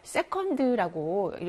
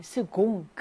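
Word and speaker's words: second: 이거는 여러분들이 영어 때문에 그 세컨드라고 읽으시는 분들이 많은데요. 이때는 스공, 그 발음이 나는 철자죠.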